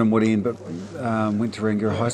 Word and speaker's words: Rangiora: Yeah, Rangiora.